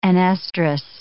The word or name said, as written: anestrous